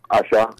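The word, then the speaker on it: Aṣa: My first one is do you pronounce it Asha? Aza? ASA? – Asha.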